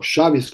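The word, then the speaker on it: Chaves: Encontramos la presencia del apellido portugués clásico Chávez, o Chávez, como se pronuncia en el idioma luso.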